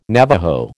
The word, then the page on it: Navajo: American English